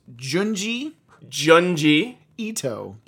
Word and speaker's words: Junji Ito: Is going to now learn how to pronounce Junji. – Ito.